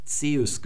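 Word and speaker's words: Zeus (Ζεύς): Und dann haben wir dieses EU, das wie in Zeus, das wird also Zeus gesprochen, das wird nicht OI gesprochen.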